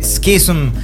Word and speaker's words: Schism: Esto fue Schism.